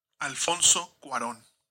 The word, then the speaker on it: Cuarón: Alfonso Cuarón.